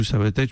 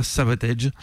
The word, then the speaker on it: Savatage: Alors je vais prononcer Savatage correctement, puisque ça se prononce pas Savatage, ça se prononce Savatage. Donc au début du concert, il y a quelqu'un qui présente le groupe, il dit bien Savatage. Il n'y a que nous qui mettons un accent anglais dans ce mot Savatage, qui se prononce à la française en fait, dans les pays anglo-saxons.